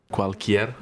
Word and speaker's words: Cualquier: Cualquier. Is that how you say it? – Cualquier. – Mm-hmm. Yeah.